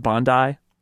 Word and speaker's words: Bondi: Did Russell tell us that it's pronounced Bondi? – That's the word on the street.